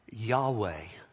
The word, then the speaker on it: Yahweh: In Hebrew, it's pronounced Yahweh, Y-A-H-W-E-H. In Hebrew, when you're writing it, you don't have vowels.